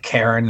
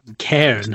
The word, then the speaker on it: Cairn: Like Karen, the K-A-R-E-N, or no, it's Cairn. – It's Cairn, isn't it? – Yeah. C-A-I-R-N.